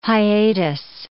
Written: hiatus

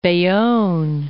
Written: Bayonne

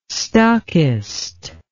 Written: stockist

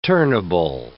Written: turnable

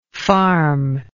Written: farmable

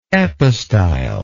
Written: epistylar

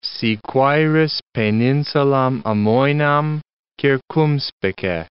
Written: si quaeris peninsulam amoenam, circumspice

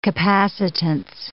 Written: capacitance

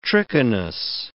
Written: trichinous

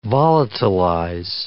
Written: volatilize

